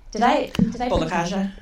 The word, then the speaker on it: Bolekaja: So, I want to first introduce Lisa Bolakaha. – Bolakaja. – Bolakaja. Well, there it is. I was close. I was trying to get you know, sort of romantic with a silent J.